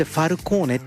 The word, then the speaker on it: FALCXNE: さあお送りしている曲はね、カナダトロントのアーティストなんですね、なんてアーティスト名読んでいいのかなってすごい FALCXNE、これでファルコーネって読むみたいなんですけどね、はい、ファルコーネで His Secrets でした。